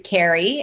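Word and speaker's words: Kerry: So you can find me on my website, which is Carrie, and my name is spelled a little differently than most. It's K-E-R-R-Y.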